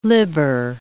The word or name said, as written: libber